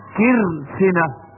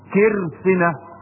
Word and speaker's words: كرسنة: كرسنة بكسر الكاف وتسكين الراء وكسر السين، كرسنة.